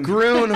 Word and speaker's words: Groen: Groon rhymes with moon.